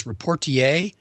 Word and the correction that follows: rapporteur: Is that right? – Rapporteur. – Rapporteur. All right.